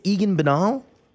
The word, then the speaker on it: Egan Bernal: I say it's Egan Bernal. – Yes, that's how you did say it.